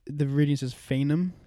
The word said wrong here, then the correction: Feynman: Fain Fain yeah. – Or yeah. Fainham. – Feynman. – Feynman. Okay. Feynman. We're butchering it.